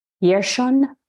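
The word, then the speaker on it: Hjerson: And so it's pronounced Yershon, I think is how you say it. But it's mm-hmm. like H-J-E-R-S-O-N, I think is the spelling.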